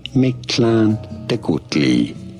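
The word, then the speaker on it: Mictlantecuhtli: How do you say it? Mictlan Tecutli.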